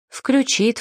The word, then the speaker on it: включит: «Включит», «включат». Здесь тоже необходимо ставить ударение на втором слоге, что услышишь нечасто.